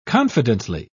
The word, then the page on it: confidently: British English